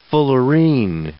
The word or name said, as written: fullerene